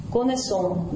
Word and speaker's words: connaissons: Помните, как я знаю, je connais, а мы знаем, ну, connaissons. Во множественном числе s начинает произноситься.